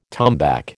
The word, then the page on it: tombac: American English